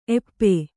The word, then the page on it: ಏಪಿ: Kannada